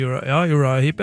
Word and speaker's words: Uriah Heep: Har du hørt om en gruppe som heter er det Uriah Heep? Uttaler jeg det riktig? – Ja, det vel Uriah Heep, ja.